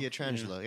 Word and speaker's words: Pietrangelo: Barry Melrose, who doesn't know how to say Petrangelo either. – Nobody does. It's not pronounced the way it's spelled, you know. That's what's weird about it. – Pietrangelo? – Pietrangelo, yeah. Because the Italian, Pietro.